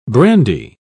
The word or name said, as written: brandy